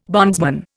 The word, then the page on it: bondsman: Indian